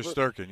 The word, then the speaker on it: Shesterkin: Shesterkin, yes.